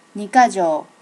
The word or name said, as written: nikkajo